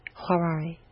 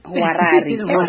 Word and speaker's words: Hwarari: Huarari. – Okay. – H-W-A-R. A-R-I. – Let's say this. – Huarari.